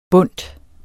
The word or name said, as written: BUNDTET